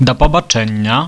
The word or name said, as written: da pabaczennia